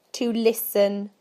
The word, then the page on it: to listen: British English